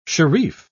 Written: sharif

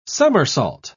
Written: somersault

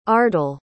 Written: ardal

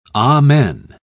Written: amen

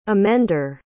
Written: amender

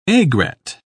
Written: aigrette

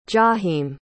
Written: jaheim

